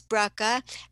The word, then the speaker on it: Bracha: But the spelling of my name is B-R-A-C-H-A. That's Brucca. And Goetz is G-O-E-T-Z. You don't pronounce the O, but it's G-O-E-T-Z.